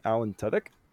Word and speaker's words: Tudyk: Alan Tudyk? – Yep, that's right.